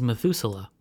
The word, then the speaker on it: MATHUSLA: The name of Curtin's new experiment, which will try and catch long-lived particles, is Methuselah. – In reference to the long-lived character from the Bible, but it's spelled incorrectly so that you can Google it unambiguously. – It's spelled M-A-T-H-U-S-L-A.